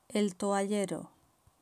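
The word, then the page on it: el toallero: Spain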